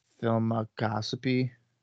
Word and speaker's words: Filmoscopy: And that it's like film, and then it's like getting a colonoscopy. So it's filmoscopy.